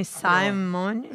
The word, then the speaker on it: Simone: Sim, Simone.